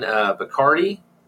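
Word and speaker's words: Bachardy: He did a conversation with writer Don Bacardi, that's how it's pronounced, called Frankenstein's Diary.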